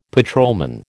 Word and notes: patrolman: American English

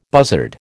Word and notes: buzzard: American English

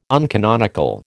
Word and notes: uncanonical: American English

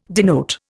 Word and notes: denote: Indian

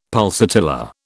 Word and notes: pulsatilla: British English

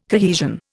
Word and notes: cohesion: Indian

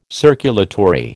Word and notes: circulatory: American English

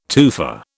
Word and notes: tufa: British English

